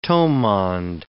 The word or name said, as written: towmond